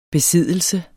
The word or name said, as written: besiddelse